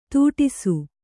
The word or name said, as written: ತುಟಿಸು